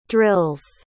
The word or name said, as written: drills